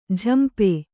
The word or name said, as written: ಜಂಪೆ